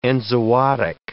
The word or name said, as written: enzootic